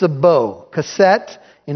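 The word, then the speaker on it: keset (קשת): "I set my bow," the Hebrew word is keset. You could spell it Q-E-S-E-T.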